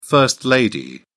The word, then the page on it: first-lady: British English